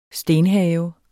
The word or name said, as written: stenhave